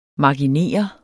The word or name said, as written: marginer